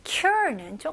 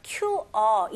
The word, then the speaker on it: cure: R는 힘을 주시고 R 발음이 혀가 이제 당기는 느낌이 나야 돼요. 그래서 QR 이게 아니고요. Cure 혀가 이제 꼬부린 느낌 나야 돼요.